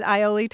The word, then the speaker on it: aioli: And I should just add that an epiphanase is the moment one realizes aioli and mayonnaise are exactly the same thing. I remember that moment. I remember that moment, and I know you can pronounce it Ioli too, so don't email us.